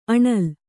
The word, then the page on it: ಅಣಿಲ್: aṇil